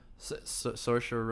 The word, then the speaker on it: Saoirse: Saoirse? – Saoirse Ronan? – Saoirse Ronan.